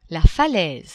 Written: falaise

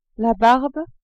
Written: barbe

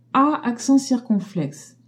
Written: a accent circonflexe

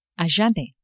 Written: a jamais

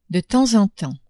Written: de temps en temps

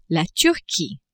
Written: Turquie

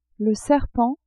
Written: serpent

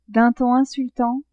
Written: d'un ton insultant

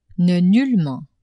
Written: ne-nullement

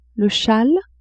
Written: chale

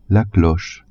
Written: cloche

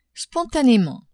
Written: spontanément

